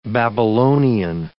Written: Babylonian

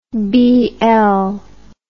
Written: bl

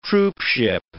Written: troopship

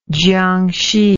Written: Jiangxi

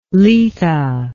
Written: letha

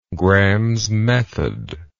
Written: Gram's method

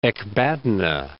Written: Ecbatana

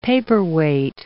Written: paperweight

